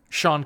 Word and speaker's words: Sean: Almost as long as S-E-A-N is still pronounced Sean. Sean Connery, not Sean Connery.